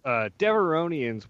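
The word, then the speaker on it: Devaronian: So Devoronians. – Devorian. I said it wrong, apparently.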